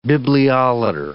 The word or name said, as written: bibliolater